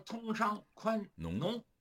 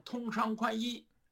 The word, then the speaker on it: 通商宽农: 你要是问他，他要脱稿，脱稿他可能说的就是病句，念的就是错别字儿，或者他不识数，哎，他得念稿，念稿还得还得有提词器，而提词器呢还得怕他发音错了，两边都给他注上音，比如说通商宽农，他给念成通商宽衣，哎，他把那农字的农。给给他念成衣服的衣，